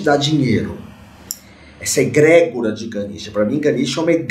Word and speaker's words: Ganesha: Om Ganesha, Om Ganesha, Om Ganesha, Om Ganesha, Om, Om, Om, que é a pronúncia correta de, do mantra.